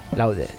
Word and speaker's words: Loaded: I Got Loaded. – Laude. – En mi pronunciación de inglés ya me han dicho bastante gente que es bastante patética. Menos mal que siempre hay alguien que me corrige. Gracias, amable.